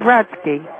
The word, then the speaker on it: Brodsky: I said Brodsky. – How do you spell that? – B-R-O-D-S-K-Y. – B-R-O-D-S-K-Y. – No, S-K-Y. – S-A-Y. – No, S-K-Y. – B-R-O-D-S-A-Y.